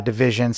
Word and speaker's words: DVSN: check out this new one by Division. It's spelled DVSN. It's pronounced Division, but the song's called If I Get Caught.